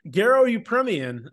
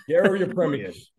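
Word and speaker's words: Garo Yepremian: Get his name right. You did make this reference, and I would appreciate Garo Yeprimin. – Garo Yeprimin.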